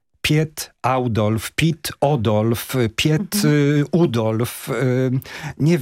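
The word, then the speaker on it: Oudolf: Jego pierwsze imię to jest Pit, a nazwisko Audolf. To dla nas nie jest takie naturalne przeciągnąć to, ale tak się poprawnie wymawia po holendersku. – Czyli Pit Audolf.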